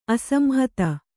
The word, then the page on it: ಅಸಂಹತ: asamhata